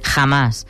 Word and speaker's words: Hamas: Hamas. L'escrivim sense accent, tot i que la pronúncia és aguda, i si va precedida de preposició, com que la H és aspirada, fem de Hamas, no l'apostrofem.